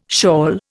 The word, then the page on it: shawl: Indian